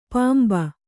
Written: ಪಾಂಬ

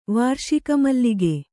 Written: ವಾರ್ಷಿಕಮಲ್ಲಿಗೆ

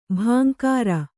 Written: ಭಾಂಕಾರ

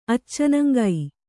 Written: ಅಚ್ಚನಂಗೈ

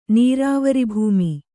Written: ನೀರಾವರಿಭೂಮಿ

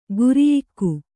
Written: ಗೋರಿಯಿಕ್ಕು

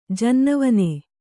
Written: ಜನ್ನವನೆ

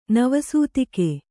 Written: ನವಸೂತಿಕೆ

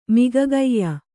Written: ಮಿಗಗೈಯ

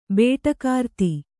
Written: ಬೇಟಕಾರ್ತಿ

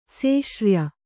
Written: ಸೇಷ್ರ್ಯ

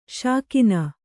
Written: ಶಾಕುನ

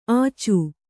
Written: ಆಚು

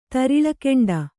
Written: ತರಿಳಗೆಂಡ

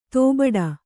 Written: ದಬ್ಬಾಡು

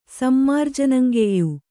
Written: ಸಮ್ಮಾರ್ಜನಂಗೆಯ್ಯು

